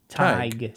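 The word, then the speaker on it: Tadhg: How to pronounce it? Tig.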